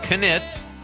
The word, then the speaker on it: Knitt: Craig Anthony Knitt, I'm saying that right, the K is not silent,